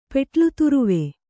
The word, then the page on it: ಪೆಟ್ಲುತುರುವೆ: Kannada